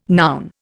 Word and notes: noun: Indian

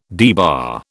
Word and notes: debar: British English

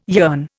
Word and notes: yearn: Indian

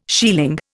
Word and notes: shieling: Indian

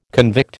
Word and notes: convict: American English

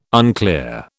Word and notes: unclear: British English

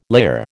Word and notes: layer: American English

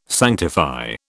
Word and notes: sanctify: British English

